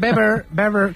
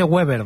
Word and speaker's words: Weber: Y está Manfred Weber, que se dice sí, Weber, sí. No se dice Weber, ¿vale? – Sí, sí. – Se dice Manfred Weber.